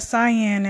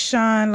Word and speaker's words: Sian: But it's not Cyan. It's Sean, like the rapper producer Sean Diddy Combs, except Sean was spelled with an I and the rapper Sean is spelled with an E. Okay. So it's S-I-A-N, Sean.